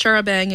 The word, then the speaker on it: charabanc: Wikipedia says it's pronounced charabang in colloquial British England, and it was an early type of motor coach.